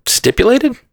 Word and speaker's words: stipulated: Stipulated? Did I say it wrong? – It's stipulated, yeah. – Yeah, I mean, I thought I said that, sorry. – It sounded like you said stipulated, like you're leaping to conclusions.